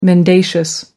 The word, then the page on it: mendacious: American English